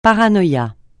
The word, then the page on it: paranoïa: francais